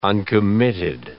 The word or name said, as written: uncommitted